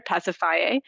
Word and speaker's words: Pasiphae: The only one that I hear people really get tongue-tied over is actually Circe's sister, Pasiphae, but she has a four-syllable name instead of three syllables, which is what I often hear people do, but I don't have a lot of worries about stuff like that.